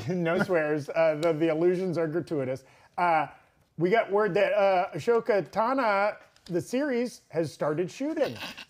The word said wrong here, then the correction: Ahsoka Tano: You said Ashoka Tana. – Is it Ashoka? – Yeah, the H is silent. – It's Tano. – Tano. – Tano, my man. – Tano. Ahsoka Tano.